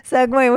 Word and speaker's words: segue: Segue, which is spelled S-E-G-U-E. – A segue? – That came up on a crossword puzzle the other night and my mind was fucking blown. Segue. – It's good. – It's not how you think you spell it.